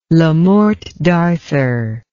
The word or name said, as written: Le Morte d'Arthur